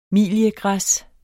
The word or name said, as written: miliegræs